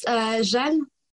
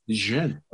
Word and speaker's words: Jeanne: Well, it's pronounced Jeanne in France. – Jeanne, okay.